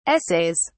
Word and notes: essays: British English

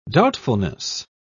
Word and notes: doubtfulness: British English

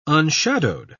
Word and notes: unshadowed: British English

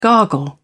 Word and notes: gargle: British English

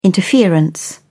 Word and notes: interference: British English

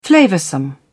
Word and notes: flavoursome: British English

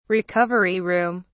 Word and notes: recovery room: British English

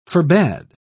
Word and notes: forbad: British English

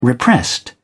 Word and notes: repressed: British English

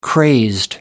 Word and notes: crazed: American English

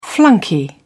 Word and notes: flunky: British English

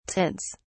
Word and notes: tints: British English

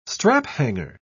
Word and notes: straphanger: British English

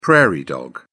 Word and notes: prairie dog: British English

